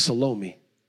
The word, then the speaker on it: Salome: It's Salome. It's kind of like salami, but doesn't taste as good. Salome, S-A-L-O-M-E, Salome. Sometimes you'll hear people pronounce it Salome, Salome.